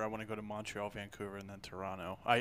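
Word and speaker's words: Montréal: I go to Montreal. That's how it's pronounced in the French. Montreal.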